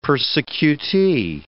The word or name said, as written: persecutee